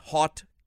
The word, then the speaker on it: haute: Make sure you're there for hundreds of global artists, hottie cuisine from around the world. – All right. Hottie cuisine. – They spelt it different. – Well, it is spelled H-A-U-T-E, hot cuisine.